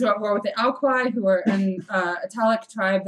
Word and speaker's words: Aequi: A-E-Q-U-I? – Alqui.